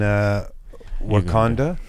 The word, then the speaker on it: Wakanda: Now, does everybody in Wakanda...